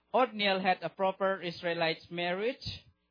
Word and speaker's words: Othniel: I think you can see in even Joshua chapter 15, 17 and Judges chapter 1, 13 also you can see that how is pronounced? Othniel or how to pronounce? O-T-H-I-N-I-E-L. Othniel.